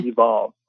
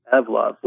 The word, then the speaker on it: Evlove: You almost want to say Evolve, but it's Evlove, which is like evolution. – Oh, Evlove.